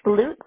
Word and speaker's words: Lutes: It's H-A-N-N-A-H. – Okay. And what's your last name? – It's Lutes, L-U-T-E-S.